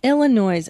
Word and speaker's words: Illinois: And then Peoria, Illinois at the Comedy Jukebox. Now, do I pronounce the S Illinois? – No.